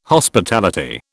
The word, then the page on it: Hospitality: British English